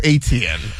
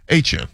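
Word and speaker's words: Etienne: So I think you could still say Travis Aitchin. I think that's the correct pronunciation. But in New Orleans, Aitchin.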